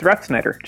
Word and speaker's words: Refsnyder: It's Refsnyder, just like it looks.